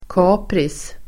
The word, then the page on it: kapris: [k'a:pris]